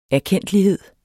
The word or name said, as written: erkendtlighed